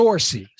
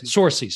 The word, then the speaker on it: Saoirse: Now, what about, I may not say her name correctly, Saoirse Ronan, who plays- Yeah, Saoirse. – Saoirse, sorry.